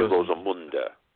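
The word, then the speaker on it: Rosamunde: In German, final E's are always pronounced. So you have to say Rosamunda, yes.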